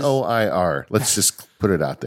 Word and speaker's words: Noir: The one that I use is called Noir. No, is that how you say it? – Noir. I would say noir, like film noir.